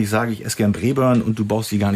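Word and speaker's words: Braeburn: Ja, Brayburn ist schon richtig ausgesprochen.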